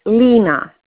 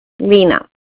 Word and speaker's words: Lena: My name is Lena. It's L like Mary, E, and like Nancy, Lena.